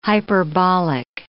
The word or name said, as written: hyperbolic